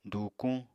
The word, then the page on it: dùukuñ: apatani